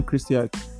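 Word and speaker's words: Krstajić: I can't pronounce it properly. – That's I don't know. – Mladen Krstić. – Is that Krstić? – Yeah, Krstić. – Mladen Krstić.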